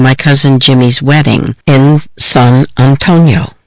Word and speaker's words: San Antonio: This was the summer of 1979, and most of us living on the border called the city San Antonio, as it's pronounced in Spanish, and not San Antonio, as I'd heard it pronounced in other places.